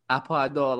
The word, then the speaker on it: Aparador: I've been there a few times, or going to Aparador, which is like the rocks. I butchered the pronunciation again. – Aparador,